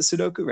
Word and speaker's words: Sudoku: the Sudokus. – Yep. I, I, love doing. Is that, am I saying it right? The Sudoku, right? Sudoku, right? Yeah, I love doing those, and uh, the crosswords are yeah fun too.